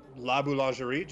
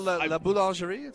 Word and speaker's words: La Boulangerie: The other day, I went to La Boulangerie just over here. I butchered that. I'm sorry. – No, you did pretty quick. La Boulangerie. It's pretty close.